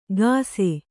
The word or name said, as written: ಕಸೆ